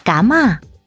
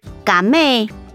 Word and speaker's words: 橘子: 橘子台语念作嘎嘛，嘎嘛，客语念作嘎妹，嘎妹。